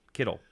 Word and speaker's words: Kittle: Ron Kettle. Kittle. Excuse me.